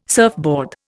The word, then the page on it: surfboard: Indian